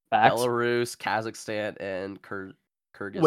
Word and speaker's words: Kyrgyzstan: Kyrgyzstan, yeah.